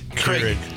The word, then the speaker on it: Keurig: Johnny brought in a coffee maker because Krieg. – Keurig. – Yeah. Keurig. Keurig. Yeah. You don't even know how to say it.